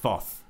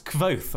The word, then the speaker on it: Kvothe: I really didn't like Kvothe. Or it's not that, it's Kvothe, apparently. I think I've been pronouncing it wrong this entire time.